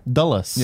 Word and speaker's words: Dulles: Dulles.